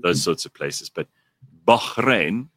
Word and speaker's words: Bahrain: I didn't know how to say it with the accent. – Bahrain. – That one.